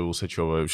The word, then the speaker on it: Усачева: Э, которая Дарья Усачева. Простите, Усачева. Сейчас реально не специально было, я опять забыл, где ударение.